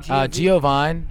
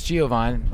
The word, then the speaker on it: Giovine: Giovine. – Giovine. – A lot of people say Giovine, Giovine, but it's Giovine. Like, but I'm open to interpretation.